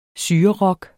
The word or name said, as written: syrerock